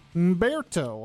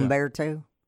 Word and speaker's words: Humberto: Tropical Storm Umberto. – Umberto, the H is silent. – Umberto. – Umberto. – It's not gotcha. Humberto.